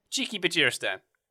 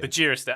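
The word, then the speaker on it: Txiki Begiristain: Chiqui Bajiristan. – Bajiristan, right, okay. – Bajiristan. I go soft G. – Right. – You're hard G, I go soft G for Bajiristan.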